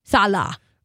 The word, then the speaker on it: Saleh: Saleh or Is it pronounced rah-rah or Saleh? – Saleh.